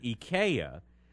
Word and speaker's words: IKEA: It's supposed to be pronounced IKEA.